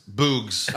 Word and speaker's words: Boogs: Which is spelled like boogs, like boogers.